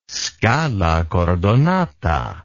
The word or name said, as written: scala cordonata